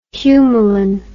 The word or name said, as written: Humalin